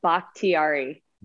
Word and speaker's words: Bakhtiari: How do you say your new last name, your married name? – Bakhtiari. – Bakhtiari. Now, Fern. – There you go. – Like bok choy.